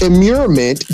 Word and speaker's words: immurement: This immurement, did I pronounce it right? – Immurement. – Yep, that's it.